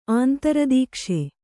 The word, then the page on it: ಆಂತರದೀಕ್ಷೆ: kannada